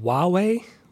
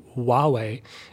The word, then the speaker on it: Huawei: But I can see why maybe people do that, because in Spanish, the H is silent. So I was thinking maybe that's why I hear some American people saying Huawei instead of Huawei, because they're not pronouncing the H. So in Chinese pinyin, they do pronounce the H, right?